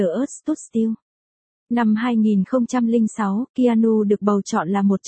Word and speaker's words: Keanu: Keanu Jack Reeves, phát âm là Keanu, sinh ngày 2 tháng 9 năm 1964, là một diễn viên Canada.